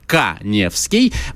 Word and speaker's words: Каневский: Просто подпишитесь на мой Инстаграм, Эдуард Каневский. Моя фамилия пишется через А.